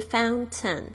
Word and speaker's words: fountain: Water fountain，OK，你会发现这个老师这个 fountain 呢用了两种不同的发音，一个是 fountain，另外一个是 fountain，fountain，为什么呢？因为这个 t 呢，其实在美式发音里面呢是有一个潜规则的，就是听 t 如果发呃放在这个 n 的发音的前面呢，它有时候是弱化，完全不说出来的。